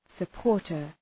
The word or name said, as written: supporter